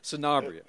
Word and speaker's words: Sanabria: Sanabria.